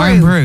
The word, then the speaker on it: Irn-Bru: Susan Boyle is hitting the high notes on Iron Brew's World Cup anthem. – Stop. – Have I pronounced that right? – What a fit. – Is it Iron Brew? – Iron Brew, yeah. – I-R-N-B-R-U.